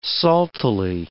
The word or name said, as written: saltily